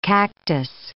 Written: cactus

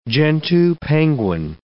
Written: gentoo penguin

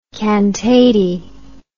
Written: cantate

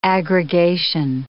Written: aggregation

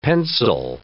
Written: pencil

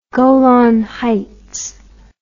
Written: golan heights

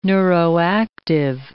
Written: neuroactive

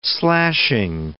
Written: slashing

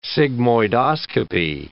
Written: sigmoidoscopy

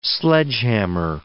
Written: sledgehammer

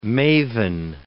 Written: mavin

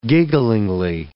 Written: gigglingly

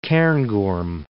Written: Cairngorm Mountains

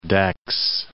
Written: Dax

